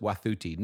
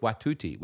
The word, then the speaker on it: Wathuti: And first thing I would say is her name is Elizabeth Wathuti, not Elizabeth Watuti, which many people are calling her, but Elizabeth Wathuti is how you pronounce it.